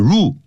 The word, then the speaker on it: rule: The new rule 这个新的规定 rule 不要念成 rule、啊、叫 rule 那个 L 在尾巴的时候叫像念欧洲的 O 但舌尖要跳起来、啊、跳起来然后呢抵在呢、嗯啊、上牙齿上排牙齿的后头